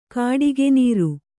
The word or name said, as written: ಗುಟುಕುನೀರು